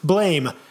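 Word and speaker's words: Blame!: Blame, Japanese, pronounced blam.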